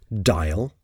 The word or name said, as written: dial